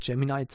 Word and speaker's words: Gemini: Mit Gemini beispielsweise. Ich habe mir das jetzt angewohnt, auch Gemini zu sagen. Irgendwie ist das die offizielle Aussprache wohl bei Google, wie auch immer.